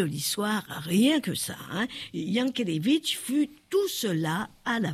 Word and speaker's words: Jankélévitch: Alors, effectivement, certains disent Jankélévitch, moi je dis Jankélévitch, puisque voilà. – Oui, le J se dit, se prononce Y, effectivement.